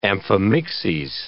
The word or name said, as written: amphimixes